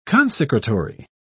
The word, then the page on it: consecratory: British English